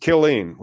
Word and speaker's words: Killeen: It's pronounced Killeen, which makes a lot more sense.